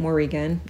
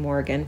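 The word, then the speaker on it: Morrígan: Anywho, some people pronounce it Morrigan. I'm just saying Morrigan for our purposes.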